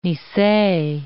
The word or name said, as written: nisei